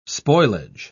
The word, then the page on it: spoilage: British English